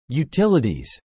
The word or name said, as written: utilities